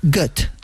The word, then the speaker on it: Goethe: Y según he visto, se pronunciaría Goethe. – Mira, bueno, como Gota.